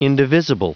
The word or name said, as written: indivisible